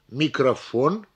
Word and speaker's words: микрофон: Произнося слово «микрофон», мы проделываем то же самое с последним слогом, который является ударным.